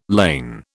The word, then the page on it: lane: British English